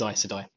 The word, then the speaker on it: Aes Sedai: Aes Sedai, yeah, no, it is Aes Sedai.